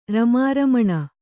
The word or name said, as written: ರಮಾರಮಣ